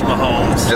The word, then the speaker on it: Mahomes: But we got Mahomes, or is it just Mahomes? I never know when I see his name. I'm not sure how you're supposed to pronounce it. – This is Mahomes.